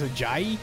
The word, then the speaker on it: Ajayi: But the guy who stole the show in this game, I watched the whole game, was running back Jay Alajaha. I say his name, Alaha. – I think it's Ajayi? – Yes. – I think that's how it's pronounced.